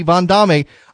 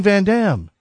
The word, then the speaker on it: Van Damme: I'm sure his name is pronounced correctly, Van Damme.